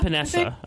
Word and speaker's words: Epenesa: How do you say that again? – Epenesa? – Epenesa. Okay, that sounds right. That sounds better.